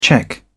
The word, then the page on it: cheque: British English